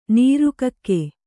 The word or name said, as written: ನೀರ್ಕಾಗೆ